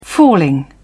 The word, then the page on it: falling: British English